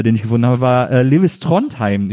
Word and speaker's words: Lewis Trondheim: Ich weiß, spricht man den so aus? – Ja, kann man. Ja. Also das ist ja sowieso ein Künstlername, der international funktioniert. Man kann Lewis sagen, kann Lewis sagen. Ja. Trondheim.